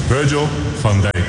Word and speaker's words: Virgil van Dijk: Virgil van Dijk.